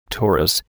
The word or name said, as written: torus